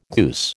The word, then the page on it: goose: American English